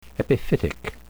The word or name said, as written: epiphytic